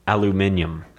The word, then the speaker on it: aluminium: People in the UK say pronounce aluminum aluminium. – Aluminium.